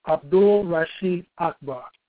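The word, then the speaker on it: Abdul Rasheed Akbar: That's Abdul Rashid Akbar. And let me spell it, A-B-D-U-L. R-A-S-H-E-E-D, and the last name is A-K-B-A-R.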